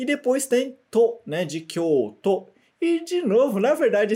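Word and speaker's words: Kyoto (京都): Né, vamos ler aqui em cima de novo em hiragana direitinho com a pronúncia japonesa, ok? Kyoto. Mais uma vez. Kyoto.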